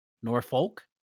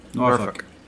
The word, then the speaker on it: Norfolk: And wait. Norfolk? – Norfolk. – Okay, got it. Nice. I'm not writing that in the show notes. – No. That's how it's pronounced though. – Yep. – That's how it's pronounced. – Oh, bless. – It is pronounced Norfolk.